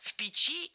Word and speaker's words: печи: Дело в том, что слово «печь» в единственном числе имеет ударение на корне, а во множественном – на окончании. Тогда, получается, нужно говорить «печи». Но не все так просто. В родительном падеже – да. Ударение ставим на первый слог. Работа чего? Печи. Из чего? Из печи. А вот в предложном падеже, но только с предлогами «в» и «на» ударение меняется. То есть «о печи», но «в печи» и «на печи». Сложно, согласна, но тем приятнее, что Сергей Стилавин все это знает.